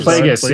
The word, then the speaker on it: Plagueis: Plagueis? Is that Darth Plagueis? Yeah, Darth Plagueis was his master, yes. And Sidious was the apprentice, yes, and then Sidious killed. Plagueis.